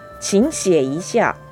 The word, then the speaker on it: 请写一下: 三声三声ですから実際にはチンシエイシア二声三声で発音し